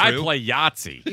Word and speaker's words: Yahtzee: It's weird that Yahtzee rhymes with Nazi.